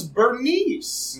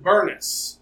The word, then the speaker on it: Bernice: But they used to pronounce it Burness. – Burness. – If they wanted to make fun of her, they would pronounce her name Burness because it sounded more like a man's name.